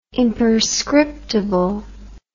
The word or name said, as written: imperscriptible